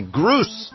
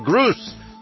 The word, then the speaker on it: Groos: Gruce, with G-R-O-O-S is how they spell it. Gruce, which some have suggested sounds like the German expression Gruf.